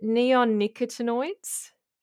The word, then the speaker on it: neonicotinoids: In particular, is I'm going to probably pronounce this wrong, but is it neonicotinoids? – Yeah, that's pretty good.